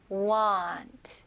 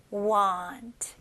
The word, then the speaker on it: want: So won't는 좀 더 o 발음이 들어가고 여기 want 같은 경우는 입술을 쓰는 것보다는 턱이 내려가는 느낌으로 want, want.